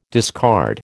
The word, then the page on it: discard: American English